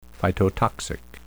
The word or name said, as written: phytotoxic